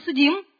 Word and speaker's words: судим: И придет время, когда каждый из нас будет судим по этой книге. Хочу сделать правильное ударение на слово «судим»,